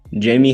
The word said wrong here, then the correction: Jaime: Jaquez Jr.? – No. Jaime Jaquez Jr. Oh my gosh, dude.